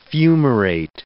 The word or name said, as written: fumarate